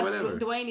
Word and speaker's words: Dwyane: You know how Dwayne is usually spelled like D-W-A-Y-N-E? His name, if you look, is D-W-Y-A-N-E, Dwayne. But it's Dwayne, but...